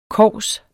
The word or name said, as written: kovs